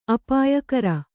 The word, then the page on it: ಅಪಾಯಕರ: apāyakara